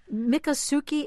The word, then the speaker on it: Miccosukee: Miccosukee Indian village.